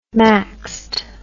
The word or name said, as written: maxed